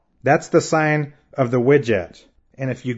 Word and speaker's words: wedjat: The first word is widget, and that word is spelled W-E-D-J-A-T, widget.